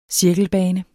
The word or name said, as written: cirkelbane